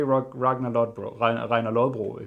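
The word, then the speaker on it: Ragnar Lodbrok: but like you're trying to portray Ragnar Lodbro, if you wanted the correct pronunciation.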